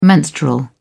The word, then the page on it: menstrual: British English